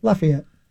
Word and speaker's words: Lafayette: Lafayette.